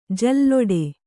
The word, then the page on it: ಜಲ್ಲಡೆ: Kannada